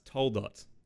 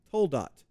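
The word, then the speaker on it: Toldot (תולדות): But you mentioned in Genesis 3:15 something about the, is it the Toledot? – Well, I have to look this up because I knew you were going to ask me. I think it's pronounced Toldot. – Toldot.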